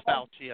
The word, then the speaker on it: Fauci: Anyway, now, Fauci, is that how you pronounce it? Dr. Anthony Fauci? – Fauci? – Ron Fauci, I think. – Yeah, Fauci.